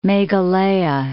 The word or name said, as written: Meghalaya